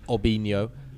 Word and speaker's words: Robinho: Similarly, the R on Brazilian names is actually an H pronunciation in Brazil. So Robinho is actually Obinho.